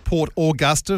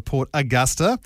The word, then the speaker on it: Port Augusta: Well, if you're saying Port Augusta, you're spelling it without the A at the start. – Well, the U, yeah. – So Augusta or Port Augusta? – Augusta.